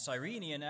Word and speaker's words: Cyrenian: And Cyrenian actually has a homophone in modern English, a word that sounds exactly the same as Cyrenian. Cyrenian, if you spell it S-I-R instead of C-Y-R, it refers to an animal in the genus Cyrenia, which includes dugongs, manatees, and sea cows.